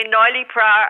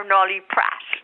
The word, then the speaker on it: Noilly Prat: It's a French drink, a Noilly Pra. – Yeah, they say Noilly Pra or Noilly Prat.